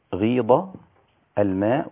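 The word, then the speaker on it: وغيض: غيظ الماء واستوت على الجدي، أي نقص ورجع إلى مكانه. غيظ الماء هنا الضاد ليست ضاء، عشان لا تقول وغيظ الماء خطأ، إنما لابد أن تنطق ضاد.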